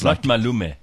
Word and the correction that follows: Maluma: No, Maluma. See, sorry, because my abuela. Was Latino, I know how to say these things.